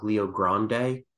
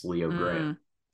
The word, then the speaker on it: Leo Grande: You know, for some strange reason, I thought it was Good Luck Leo Grande because of how it's spelled, but it's Leo Grant.